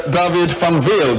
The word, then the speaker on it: David van Weel: Thank you very much, David van Weel.